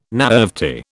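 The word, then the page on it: naïvety: British English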